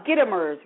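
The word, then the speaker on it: Gitomer: The Little Red Book of Selling by Jeffrey Gitmore. Let me see. I don't want to mispronounce his name. Gittimer's, right. Jeffrey Gittimer, Little Red Book in Selling.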